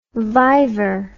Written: vivor

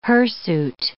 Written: hirsute

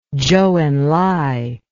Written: Zhou Enlai

